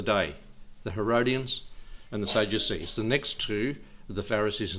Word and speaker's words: Sadducees: These are the Sadducees. I pronounce it like that because they were a sad lot.